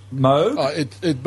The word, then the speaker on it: Moog: Both of them are acceptable. Robert Moog says that parts of his family pronounce it Moog, but it's a Northern European name anyway. So, yeah, and his family pronounce it alternatively, so it doesn't really matter.